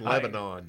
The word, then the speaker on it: Lebanon: That's how they pronounce Lebanon. Tennessee is Lebanon.